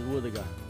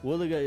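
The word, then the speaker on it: wuthuga: Our local Mulgana word today is woodiga. What is a woodiga? Woodiga is a dugong.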